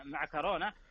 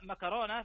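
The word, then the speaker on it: معكرونة: إيه هذا دائما يمكن نسمع دائما كثير يتحدثون عنها، خلنا ناخذها اول شيء النطق الاصح يعني وش او الاكثر شيوعا هو بالكاف طبعا مع كورونا، هذا النطق الصحيح ويمكن الدارج في اغلب الدول. اما مكرونا فهو نطق دارج منتشر في بعض اللهجات ويستخدم كثيرا في الحديث اليومي، لكنه يعني اقل، يقولون اقل فصاحه من حيث التعريب المتداول.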